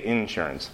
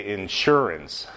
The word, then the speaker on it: insurance: You say insurance. Most Northerners will say insurance, putting the accent on the second syllable. In the South, in North Carolina, people pronounce it like you just did.